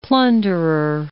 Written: plunderer

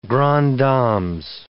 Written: grandes dames

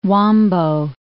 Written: Huambo

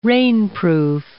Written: rainproof

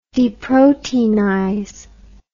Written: deproteinize

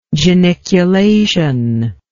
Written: geniculation

